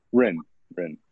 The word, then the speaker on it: Hryn: Hryn.